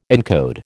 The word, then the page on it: encode: American English